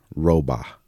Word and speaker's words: Rohrbaugh: This commentary is from a gentleman called, his name is actually Roba. And his... you spell it Rohrbaugh.